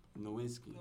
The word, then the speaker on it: Nowitzki: Nowitzki.